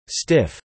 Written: stiff

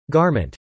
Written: garment

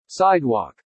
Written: sidewalk

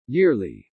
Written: yearly

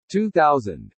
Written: 2000

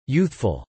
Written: youthful

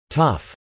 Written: tough

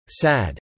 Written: sad

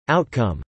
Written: outcome

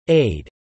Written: aid

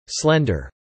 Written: slender

